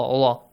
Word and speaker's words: Allah: The word, the letter lam and the word Allah should be pronounced with an a sound. Allah, Allah.